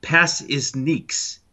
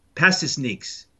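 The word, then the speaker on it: Pasečņiks: His last name is pronounced Passisniks. Passisniks. So it's sort of like pass is, and then Dominiques, you know, like the Neeks.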